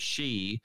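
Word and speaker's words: she (四): His girlfriend Mizuho, who's one of the titles, receives a text from a number all fours, which in Japanese the word for four is she, pronounced the same way as their word for death.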